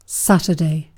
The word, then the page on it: Saturday: [ˈsæt.ɚˌdeɪ]; /ˈsætədeɪ/; /ˈsætədi/; /ˈsætɚdeɪ/; /ˈsætɚdi/